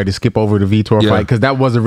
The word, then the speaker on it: Vitor: Remember, because I was pronouncing his name wrong. – Oh, yeah. Okay. – Yep, I picked Vitor. – Oh, I was calling him Victor.